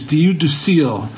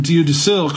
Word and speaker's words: Dieu du Ciel: But the one place you definitely have to go in Montreal is Diu du Ciel. It's spelled D-I-E-U-D-U-C-I-E-L.